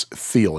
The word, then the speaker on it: Thiel: I was saying David Thiel, it's Thiel. And his name, the TH, is pronounced like a real TH.